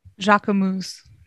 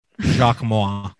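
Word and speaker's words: Jacquemus: How do you actually say this brand name? I've said it so many times wrong that I can't remember. – Jaquemus. – Jaquemus. – Yeah. – Jaquemus. – Jacquemois is how I pronounce it. Which I will never forget wrong. That, because like how does the U make a OI sound? – To be honest, I said it with confidence.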